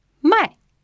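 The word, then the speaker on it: mai (卖): To buy has got the third tone. It sounds like this, mai. And to sell has got the fourth tone, and it's pronounced mai. So you have mai to buy and mai to sell.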